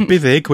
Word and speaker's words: Buddug: or Bithig, which is spelt Buddug, or Cranogwen, who sounds like a really angry, hatchet faced old woman, I think, or.